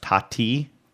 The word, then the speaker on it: Tati: And I'm just getting into too much here, but apparently the guy who made this, I guess his last name pronounced Tati, T-A-T-I,